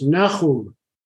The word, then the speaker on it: Nahum: So then here is your grandfather, Nahum Vaskovich. – No, that's pronounced Nahum. – Nahum. Okay. – That middle consonant is one that doesn't exist in English, so many English speakers, but it's Nahum.